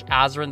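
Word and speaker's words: Azren: That is spelled P-O-L-Y-G-L-O-T. A-Z or Z, depending on your country, R-E-N. You can also simply search Azrin the Language Nerd on whatever your favorite social network is.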